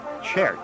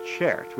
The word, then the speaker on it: CZERT: It's pronounced Chert. Chert, which sounds like the Czech word cert.